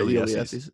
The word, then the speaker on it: Ulysses: It's a 3-part miniseries on your boy Ulysses. – Ulysses? – No, that's how you say it. – Yeah, Ulysses.